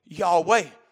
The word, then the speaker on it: Yahweh: So we said, it's Yahweh. This is how you say it in Hebrew Yahweh. But this is how we're going to say it in English Yahovah.